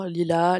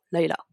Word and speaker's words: Lila: Et c'est à ce moment-là qu'il va tomber sur euh, le deuxième personnage principal, qui est Laila encore une prononciation dont tout le monde n'est pas d'accord, Lila, Laila, je dis plutôt à l'anglaise en disant Laila.